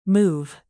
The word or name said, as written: move